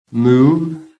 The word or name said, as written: MOVE